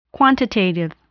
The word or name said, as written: quantitative